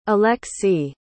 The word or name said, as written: aleksy